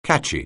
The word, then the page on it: catchy: anglais